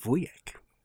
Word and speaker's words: wujek: Yes, and the Polish word for uncle is wujek. – Wujek. – Yeah. Oh, good pronunciation.